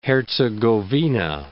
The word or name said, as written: Herzegovina